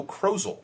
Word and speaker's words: Krozel: It was designed by Joe Krozel, rhymes with puzzle.